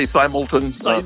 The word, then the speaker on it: Simelton: I say Simelton.